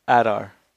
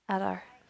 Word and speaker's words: Adar: Adon, Adar, whatever, the father of the orcs. – Adar. – Adar. – Yes. Adar.